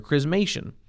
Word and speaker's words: chrismation: And if it sounds like the word Christ is in there, chrismation, all right?